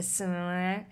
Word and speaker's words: sommelier: He thinks he's, I'm going to say this wrong, a sommelier. How do you say that? – Sommelier.